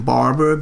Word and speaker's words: Barber: Well, my name is spelled C-H-U-K. Last name Barber, B-A-R-B-E-R.